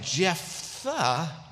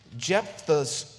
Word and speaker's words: Jephthah: It's spelled Jephthah. I don't know who thought to put PH before TH. I guess it's like Jephthah.